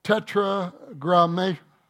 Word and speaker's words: tetragrammaton: By the way, in the Greek, it's called, now, again, I was an English major, so I'm going to try to pronounce this as best I can. It's called tetragrammaton. Tetragrammaton.